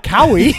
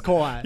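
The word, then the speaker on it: Kauai: Yeah, you made I was like, it doesn't rhyme with Maui, right? It's not Cowie. – No, it's Kawhi.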